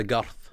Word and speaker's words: girth: We'll be talking about the guth of my beard soon. – Aye, the girth. – What a word. – You forced me to actually say it correctly.